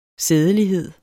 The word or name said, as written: sædelighed